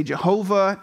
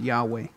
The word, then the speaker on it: Yahweh: Now she prays to the Lord, and pay attention again, all capital L-O-R-D means the name of God, Y-H-W-H, just four letters in Hebrew. People used to say Jehovah, now they think pronunciation is probably closer to Yahweh, the name of God, Yahweh.